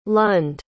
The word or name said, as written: lund